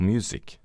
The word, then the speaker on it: music: Veldig mange nordmenn sliter med denne lyden, og bruker gjennomgående den ustemte S-en i ord som this, is, busy, cousin og music er alle ord som uttales med en stemt S, altså these, is, busy, cousin og music.